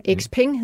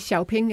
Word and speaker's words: XPeng: XPeng hedder selskabet. Xiaopeng er også en anden måde at udtale det på.